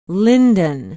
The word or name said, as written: lyndon